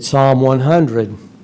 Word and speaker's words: Psalm: So it is P-S-A-L-M. No, P-S-A-L-M, yeah. That's what it is.